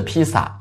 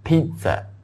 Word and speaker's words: pizza: 我想提来一下发音哈，不是 pizza，也不是 pizza，是 pizza，有点像那个我们说那个啊、三点水一个，我们说那个原来那个我们说泽啊，这泽这个字的发音哈，pizza pizza OK 啊 pizza 啊，沼泽那个泽啊 pizza OK 有点像这样的发音啊 pizza。